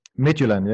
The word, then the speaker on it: Midtjylland: Did I pronounce that right? Midtjylland? – Midtjylland, yeah. So you know, pronunciation top draw.